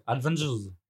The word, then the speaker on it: Avengers: Bah, mais on prononce comme ça en anglais. Avengers. – Avengers. – Avengers. Les Vengeurs. – Bah, t'enlèves le D, on est bon.